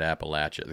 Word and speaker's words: Appalachia: The Kentuckys want you to say it Appalachia. The Kentuckians, Appalachia.